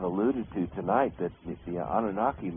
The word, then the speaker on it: Anunnaki: And it was suggested that not only did beings that one you referred to as the how do you pronounce that, the Anunnaki? – Anunnaki. – The Anunnaki. I'm not saying that properly, they corroborate that, but they also go on to suggest that there were many others.